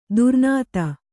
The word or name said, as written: ದುರ್ನಾತ